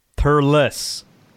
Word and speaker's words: Thurles: Thurles.